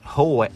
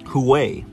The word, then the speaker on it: Huawei: investment in Chinese companies that aid the military, and rallied allies to exclude Chinese tech giants Huawei. I'm sorry, I keep pronouncing that wrong. From their 5G mobile networks. Huawei. There we go.